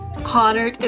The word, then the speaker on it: Hahnert: Hahnert is correct.